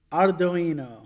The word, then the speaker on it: Ardoino: Ardoino.